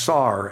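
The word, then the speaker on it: sar (שר): And that word in Hebrew is simply pronounced sar, S-A-R.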